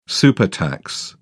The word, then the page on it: supertax: British English